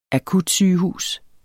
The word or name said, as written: akutsygehus